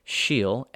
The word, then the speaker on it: Síol: And another word that's more of a figurative word than clan or slíocht is síol, which is S-I-O-L. In Gaelic, when you have the I and the S next to each other, like the name Sinead, it also works with E's, like you see with Sean, when it's spelled the Irish way of S-E-A-N. Okay, so that's what's going on here. Síol, S-I-O-L, it means seed.